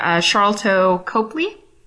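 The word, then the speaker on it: Sharlto Copley: We've got some interesting box office results from the weekend, reviews of Popstar and Me Before You, an interview with Power star Charlto Copley.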